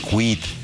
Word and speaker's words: Kwid: Al Renault Kwid, Kwid se pronuncia Kwid, le dicen carriola, ok.